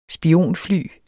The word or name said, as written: spionfly